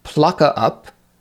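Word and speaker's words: plocka upp: Uh, the first one is plucka up, and maybe with a Swedish accent it has more intonation than that, but plucka up, which in English means pick up, very similar actually to the English, and the second verb is yaga.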